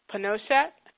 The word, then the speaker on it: Pinochet: Pinochet,